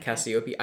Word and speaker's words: Cassiope: You think it's Cassiope with the emphasis on the O? – Yeah. Cassiope.